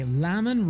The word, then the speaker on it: Lamin: Lamin rhymes with jammin', you've gotta love it.